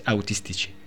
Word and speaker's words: Autistici: Y nada, primero lo sé que la pronuncia es muy difícil para cualquier persona que no hable italiano, pero es Autistici. – Ah, con una CH, sí.